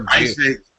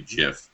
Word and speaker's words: GIF: What is the right way to pronounce GIF? Is it GIF or GIF? – I say GIF, but I heard the on the radio last week, it's supposed to be GIF. The guy who invented the format pronounces it GIF.